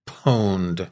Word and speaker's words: pwned: So it's the word owned. When you're owned, someone is taking control of your computer. But in hacker spelling, it's with a P, so pwned.